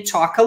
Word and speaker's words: talk: Então, the adults eat, drink and talk a lot. Então, os adultos comem, bebem e falam muito. The adults eat, drink and talk a lot. Lembra que não vai pronunciar o L aqui. Talk, somente.